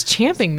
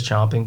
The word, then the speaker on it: champing: What does champing mean? – Pronounce I-N-C-O-N-G-R-U-E-N-T. – Incongruent. – There you go. – Champing? – That's the expression. Everybody says chomping, but it's actually champing.